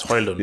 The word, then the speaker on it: Højlund: Aha, it's Højlund, yeah. He's on video saying you're not supposed to say the D, but Højlund. I will probably say Højlund until I'm on, you know, television and someone puts a microphone Højlund in my face.